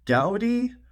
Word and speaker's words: Gaudi: They came out, they have a new model, or not a new model, a new chip that's called Gaudi? Gaudi? It's basically the car brand Audi, but with the G in front of it.